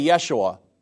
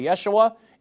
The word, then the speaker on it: Yeshua: In the Hebrew, you would say Yeshua if you were pronouncing the name of Jesus. In Hebrew, you would say Yeshua. In English, you say Jesus.